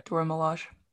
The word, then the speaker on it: Dora Milaje: Dora Milaje? – Yes. Yeah. Okay. Dora Milaje.